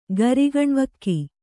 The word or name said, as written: ಗರಿಗಣ್ವಕ್ಕಿ